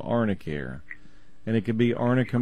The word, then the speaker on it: Arnicare: Yeah, it's spelled A-R-N-I, and then the word care, Arnicare.